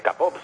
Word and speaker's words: K-pop: Te decía antes lo de el tema de del arte de la espada, del esgrima antiguo, y ahora concierto, no sé si lo digo bien, Q-pop. – De K-pop, sí.